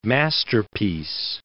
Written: masterpiece

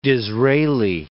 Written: Disraeli

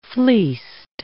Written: fleeced

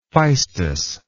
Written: phaistos

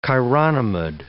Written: chironomid

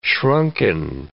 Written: shrunken

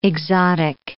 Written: exotic

